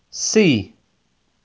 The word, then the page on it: C: Inglês